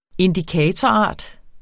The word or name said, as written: indikatorart